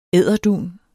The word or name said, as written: edderdun